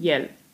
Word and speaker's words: iel: Różnice widać tylko na piśmie, w wymowie nie ma żadnej, niezależnie od pisowni i od liczby wymawiamy ten zaimek tak samo, czyli jel.